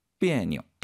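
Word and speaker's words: 비에뇨 (别扭): 역시 그 니오자의 발음 경성으로 읽는다는 거 가볍게. 그래서 비에뇨.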